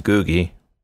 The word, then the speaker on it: Googie: And you do pronounce it Googie. I know that now.